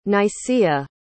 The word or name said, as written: nicaea